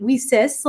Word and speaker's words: WiCyS: We often go by our acronym, WISIS, and we spell that W-I-C-Y-S, pronouncing it WISIS like we sisters, because we're a global cyber sisterhood.